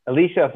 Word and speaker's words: Elisha: Elisha. – So Elijah and Elisha. Okay. So we're going to quote a story. As you know from the story of Eliyahu and Elisha, when Eliyahu was going to tell Elisha, you're going to be the next prophet of the Jewish people after me.